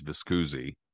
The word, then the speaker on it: Viscusi: but like you said, um, Williams moves on to Florida early on, and as he hooks up with Lou, how do you pronounce his last name again? Because I'm gonna mess uh, up. I think it's. I'm pretty sure it's Viscusi.